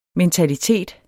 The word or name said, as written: mentalitet